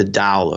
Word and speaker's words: Dao: And you mentioned that I've come up with this new edition of the Twitter book, the Tao of Twitter, or the Chinese pronunciation is the Dao of Twitter, but Tao is a little more fun to say.